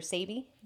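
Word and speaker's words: Sebi: Also known as Dr. Sabi. – Yes. – I say it right? – Uh-huh.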